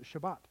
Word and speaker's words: Shabbat: They're called Shabbat elevators because over there they pronounce it Shabbat.